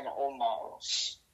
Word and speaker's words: Omos: But anyway, all seriousness, Brock Lesnar's going to face the big man, Omos.